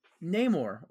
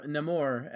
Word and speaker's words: Namor: Namor, or Namor, as he's pronounced in this movie.